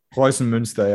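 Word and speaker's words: Preußen Münster: Was it Prowson? Is that how you pronounce it? Prowson Munster? – Prowson Munster, yeah.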